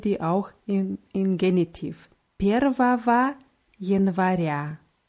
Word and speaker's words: Первого: Und dieses Первого wird mit Ws am Ende mit zwei Ws ausgesprochen, aber geschrieben wird es mit einem G zwischen zwei Ws.